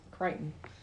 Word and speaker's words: Crichton: Although this might be real science, because Crichton. Did I say it right that time? – Crichton. – Why do I see... – Just get rid of the ch.